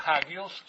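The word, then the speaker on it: Hagios (ἅγιος): Esta palabra Hagios, o sea, así se pronuncia.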